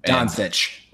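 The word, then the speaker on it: Dončić: So they're going to be out of the, I'm going to butcher his name, Luke Dokic, Dosich. I can't. – Doncic. – Doncic.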